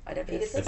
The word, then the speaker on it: Oedipus: Oedipus.